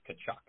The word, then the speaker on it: Tkachuk: Calgary, they have this guy, Matthew Tkachuk. It's spelled with a T, but it's pronounced Tkachuk.